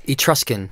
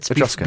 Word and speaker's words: Etruscan: Apparently, works of art from Greek, Roman, and I don't know how to pronounce this next word. – Etruscan. – Yes. – Oh, I was just guessing. – He knew. Is it Etruscan? – So that's Etruscan, before the Romans came.